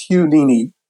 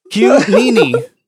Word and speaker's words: Nini: It's Hugh Nini. – Hugh Nini. – Yes. – Hugh Nini. Yeah. – Like Houdini, but like Houdini. Like Houdini.